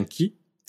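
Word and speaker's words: Ki (木): Donc là, le kanji de l'arbre se prononce bien Ki.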